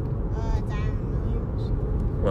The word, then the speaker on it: Zion Williamson: Zion Williamson.